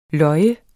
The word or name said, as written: løjer